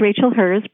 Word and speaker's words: Rachel Herz: My name is Rachel Herz, pronounced like his and hers.